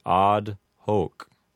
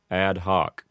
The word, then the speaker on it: ad hoc: If you wanted to use a correct Latin pronunciation, you might say ad hoc. And you would give the word odd a long A sound, like odd. And then the O in hoc would have a long O sound.